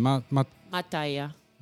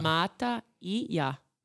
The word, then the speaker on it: Mataija: Mataija. – Mataija. – Mataija.